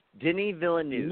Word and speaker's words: Denis Villeneuve: How you pronounce the director? – Denis Villeneuve. – Not like. – Ah, there we go.